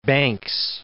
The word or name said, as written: Banks